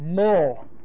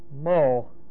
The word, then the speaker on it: mul: The word itself, circumcise, the word itself is from the word mul. Mul. M-U-L, long U. Mul.